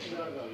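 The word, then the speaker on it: Sonargaon: Sunargaon. Is that how you say it? Or is it Sunargaon? – Yeah, Sunargaon. Yeah. – Okay, I better say it properly. Shunargaon, right? In Bangladesh, to the Nilab in Sindh in Pakistan.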